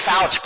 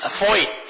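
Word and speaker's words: Feucht: I say Fouch, but it's Foyt.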